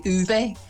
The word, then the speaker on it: Ove: I think they changed his name, because I think it's actually Ove, if you say it properly in Swedish, which I obviously don't.